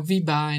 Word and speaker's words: Vibha: I've end up pronouncing my own name in France without the H. So, you know, I just say, you know, Vibha, and it's written V-I-B-A, and they miss out the H.